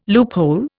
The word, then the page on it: loophole: Indian